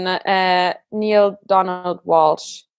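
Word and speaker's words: Neale Donald Walsch: Wow, okay, also es gibt 2, aber eins ist für mich das absolute Lieblingsbuch ist Gespräche mit Gott von Neil Donald Walsh. Mhm. Ich glaube, so spricht man ihn aus. – Ja, ja.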